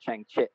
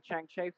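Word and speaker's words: Chang Chit (張徹): You know, I, I, think they pronounce it Chang Chit. Uh, you know, when, you you speak to somebody in, in Cantonese, yeah. so it's like yeah, like what about the Chang Che films?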